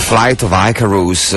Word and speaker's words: Flight of Icarus: The Flight of Icarus.